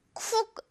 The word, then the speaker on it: cook: 발음 어떻게 한다? cook 그렇죠. cook 아니고 cook 우 이렇게 짧습니다.